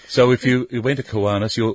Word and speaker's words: Kiwanis: That's K-I-W-A-N-I-S.